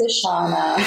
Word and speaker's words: Ishana: Like they're always like, Ish. Am I pronouncing that right? I'm like, no, it's Ishana. Very simple.